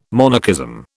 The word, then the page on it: monarchism: British English